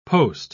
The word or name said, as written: post